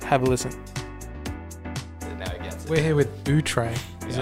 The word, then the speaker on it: Outré: But we've Australianized the name and we just pronounce it Outré.